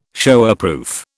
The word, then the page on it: Showerproof: British English